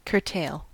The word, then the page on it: curtail: American English